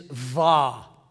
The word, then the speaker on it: Vav: The sixth letter of the Hebrew alphabet is Vav. We pronounce it with a V, but in English, we would make it look like a W.